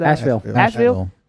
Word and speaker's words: Asheville: Because if the end goal, and then think about Asheville? What's how you say it? – Asheville.